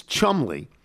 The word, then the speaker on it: Cholmondeley: Cholmondeley is Chumley.